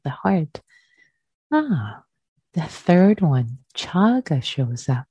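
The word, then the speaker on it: cāga: One should cultivate relinquishment / generosity, chaga, chaga, in Pali, C-A-G-A. C is pronounced as cha, chaga, chaga, the long A is the first A. And the fourth aditana, fourth resolve, one should train for peace, upasama.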